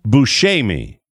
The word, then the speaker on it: Buscemi: In an interview several years ago, he said that he grew up with his name being pronounced Buscemi, but it's an Italian name, and in Italy, it would be pronounced Buscemi. Mm. So, Buscemi.